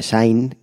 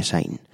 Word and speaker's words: Syn: Ellos son The Sign, creo que se pronuncia así. Es como sin, como pecado, pero en vez de I latina, Y, The Sign.